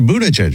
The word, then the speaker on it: Buttigieg: Will you please play your Buttigieg soundbite for me? It's hilarious." – Buttigieg. – That's how you pronounce it.